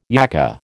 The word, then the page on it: yakka: American English